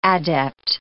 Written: adept

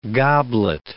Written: goblet

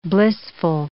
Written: blissful

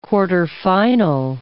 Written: quarterfinal

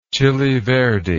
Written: chili verde

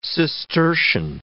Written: Cistercian

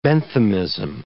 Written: Benthamism

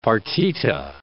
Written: partita

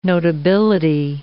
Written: notability

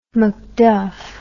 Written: macduff

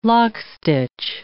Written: lockstitch

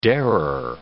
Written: darer

